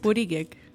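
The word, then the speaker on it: Buttigieg: Burigek.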